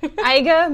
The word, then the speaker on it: Eiger: Eiger, Mönch, and Jungfrau.